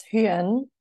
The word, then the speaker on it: Huyên: So my name is Huyen. Full name and the Vietnamese pronunciation is Nguyen Thit Minh Huyen.